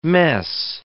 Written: mess

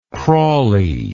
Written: crawly

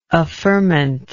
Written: affirmant

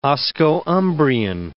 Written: Osco-Umbrian

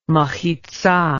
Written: mechitzah

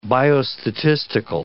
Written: biostatistical

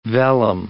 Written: vellum

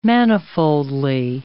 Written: manifoldly

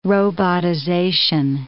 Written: robotization

